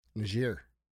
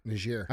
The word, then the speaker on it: Niger: Niger. – Shit, man. Fuck. Is that really how you say it? – Yeah. Niger.